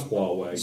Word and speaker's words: Huawei: It's Huawei.